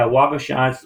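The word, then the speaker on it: Waugoshance: I'm looking at one right now that looks like it had poured or reinforced concrete, the Wagoshans Lighthouse. Am I pronouncing that correctly? – Yeah, Wagoshans was not poured concrete.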